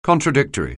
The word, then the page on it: contradictory: anglais